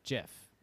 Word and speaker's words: GIF: Now, apparently, though, some people have said that the person who invented the GIF, as I said it's pronounced with a J, GIF. Or like a lychee.